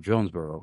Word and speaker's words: Jonesboro: Is it Jonesboro or Johnsonboro? Jonesboro, correct? – Oh, Jonesboro, you got it.